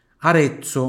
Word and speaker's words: Arezzo: Arezzo, onde tem Z aqui, tem som de TS.